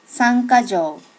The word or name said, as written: sankajo